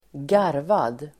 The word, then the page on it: garvad: [²g'ar:vad]